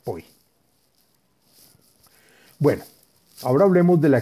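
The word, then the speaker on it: Poi (פוי): Y es el santo nombre de las letras Pei Vav Yud, se dice y se pronuncia Poi.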